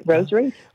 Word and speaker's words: Rose Reif: Rose Reif.